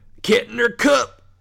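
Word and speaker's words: Kettner Kupp: It sounds like you're saying, like, get your cup. Like, Kettner Cup over here. Where's that? Where's Sally? Oh, she's Kettner Cup.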